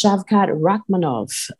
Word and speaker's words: Shavkat Rakhmonov: Well, what kept me awake on the prelims was definitely someone that I have my eye on, Mike, and that's Shavkat Rakhmonov.